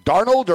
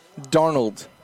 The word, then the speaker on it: Darnold: Darnold or Darno? – Darnold. – Darnold. – Thank you. – Yeah, that's it. – But Darnold. – You have heard sort of people sort of pronounce it like that, right? Like Darno?